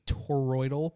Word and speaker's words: toroidal: These new propellers are what's called a toroidal propeller.